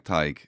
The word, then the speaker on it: Tadhg: I do like Tadhg. I think it has that. It's one of the few names where Irish people pronounce the TH in it. You know, they'll give it the full the full whack.